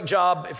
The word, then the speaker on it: Job: Somebody said, Job's my favorite book. You are a sicko. It looks like Job, for those who are new to the Bible, but it's actually pronounced Job.